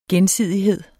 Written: gensidighed

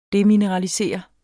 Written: demineralisere

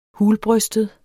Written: hulbrystet

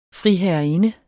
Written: friherreinde

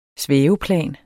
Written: svæveplan

